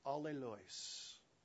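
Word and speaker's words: allēlōn: It's pronounced alleluis. Alleluis. It's translated into English as two words, one another. Alleluis. Say it with me.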